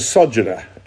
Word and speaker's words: sojourner: Just by the way, friends, if you don't know how to say a word in English, put the emphasis on the first syllable. It's a sojourner.